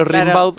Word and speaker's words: Rimbaud: Gimbaud. – Ahí va. – Como se pronuncia Rimbaud, pero Rimbaud.